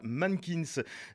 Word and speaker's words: MNNQNS: Et voilà, à l'instant, c'était le groupe rouennais Mannequins, que vous écrivez M-N-N-Q-N-S et que vous prononcez tout simplement voilà, Mannequins, sur les ondes du mix des cultures avec un extrait.